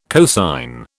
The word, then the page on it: cosine: British English